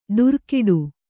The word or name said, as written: ಡುರುಕಿಡು